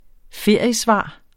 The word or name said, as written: feriesvar